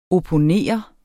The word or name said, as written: opponere